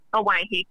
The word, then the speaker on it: Owyhee: Am I pronouncing this word correctly? Owyhee? – Owyhee, correct.